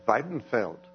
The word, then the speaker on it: Weidenfeld: And the way you really pronounce his name is Weidenfeld.